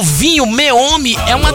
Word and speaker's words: Meomi: Então, se você quiser experimentar um vinho gostoso, chama Meome. Meome. M-E-O-M-I. Chama Meome. Meome. Como se falasse meio homem.